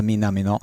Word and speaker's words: Minamino: It's pronounced Minamino.